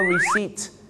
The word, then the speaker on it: receipt: ดวิดสันต้องเข้าใจนะครับคุยพี่น้องชาวไทยก็ต้องฮาร์เลย์เดวิดสันแต่ถ้าฝรั่งเนี่ยนะครับก็จะออกเสียงว่าฮาร์ลีย์เดวิดสันนั่นเองครับคำตอบคำที่ลงท้ายด้วยนะครับที่ด้วยตัว BT หรือ PT เนี่ยนะฮะอยากจะฝากบอกนะครับว่าตัว P กับตัว B นะครับหลายๆครั้งไม่ได้ออกเสียงนะฮะอย่างเช่นคำนี้ครับคำว่า receipt ที่แปลว่าใบเสร็จนะครับบ้านเราชอบออกเสียงว่า receipt นะฮะ receipt ไม่ใช่ receipt นะฮะก็จะออกเสียงว่า receipt นะครับผ